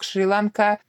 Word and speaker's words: Шри-Ланка: Да, остров Шри-Ланка или Шри-Ланка, вот мы сейчас готовили, смотрели, да, оказывается, в этом слое все-таки правильным вариантом является ударение на последний слог Шри-Ланка.